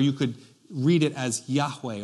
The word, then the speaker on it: Yahweh: So in your Bible, when you see where it says the Lord, all capital L-O-R-D, that's the God's name. And it's pronounced something like Yahweh. Or some traditions would pronounce it something like Jehovah. Probably Yahweh is probably a better way to pronounce that.